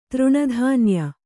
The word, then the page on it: ತೃಣಧಾನ್ಯ: kannada